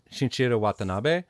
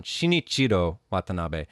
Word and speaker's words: Shinichiro Watanabe: Shinichiro Watanabe, who was the director for Cowboy Bebop. – Oh, okay. – Yeah. I said his name wrong. Shinichiro Watanabe.